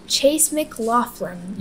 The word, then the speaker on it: Chase McLaughlin: Chase McLaughlin.